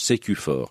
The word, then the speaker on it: Sécufort: Ça s'écrit S-U-C-U. 4, hein, puis ça se prononce Sécufort.